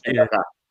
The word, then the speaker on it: Padaca: yun ang tamang pronunciation, uh, Padaka. Kasi po, kaming mga taga dito sa probinsya, especially kaming mga Ilocano, padaka, ganyan.